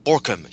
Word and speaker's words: Bauckham: I will cite the great scholar, Richard Bauckham, I think it's pronounced Borkham here.